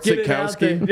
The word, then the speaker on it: Sitkowski: Art Sitkowski.